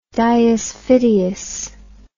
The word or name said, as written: diusfidius